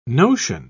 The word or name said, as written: notion